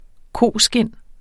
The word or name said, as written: koskind